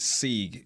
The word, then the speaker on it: Sieg: Potentially bringing in Trent Sieg. Am I pronouncing that right? – S-I-E-G? Yeah, I believe so, yeah.